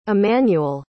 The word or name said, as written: emmanuel